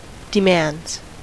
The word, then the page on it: demands: American English